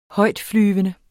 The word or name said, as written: højtflyvende